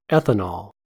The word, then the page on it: ethanol: American English